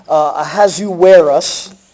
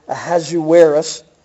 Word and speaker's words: Ahasuerus: Ahasuerus, that's the name, that's the pronunciation. I have to keep saying that over and over, Ahasuerus.